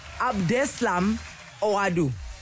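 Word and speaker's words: Abdeslam Ouaddou: Am I saying that right? Abdeslam Oadu. – That's it.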